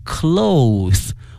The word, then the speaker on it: cloth: 同学们同时对比一下衣服，它的拼写呢是 C-L-O-T-H-E-S，但是它的发音是 cloth，而如果指布的话呢，它的发音是 cloth，哎 cloth，哎，这是在考试的时候呢这个变音经常出现的这么一个考点，希望同学们呢加以注意哈。